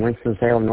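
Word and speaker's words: Winston-Salem: Winston, Salem, North Carolina? – You got it right. Winston, Salem, North Carolina.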